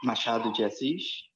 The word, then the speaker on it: Machado de Assis: Machado de Assis.